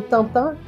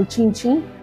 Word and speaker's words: Tintin: assim, dos quadrinhos do, do Tantan, quero dizer, do Tintin. É, é a pronúncia francesa, foi mal.